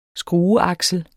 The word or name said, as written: skrueaksel